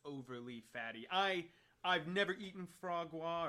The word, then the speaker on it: foie gras: I'm just gonna play the proper pronunciation. – I'm on the edge of my seat. – Yeah. Here we go. – Foie gras. – So it even gives you F-W-A-A-G-R-A. Foie gras.